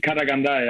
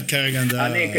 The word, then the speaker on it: Karaganda: In Koranga. Nee, Karaganda, ja. Das ist Kasachstan. – Ah, Karaganda. – Karaganda ist die Stadt im Kasachstan halt.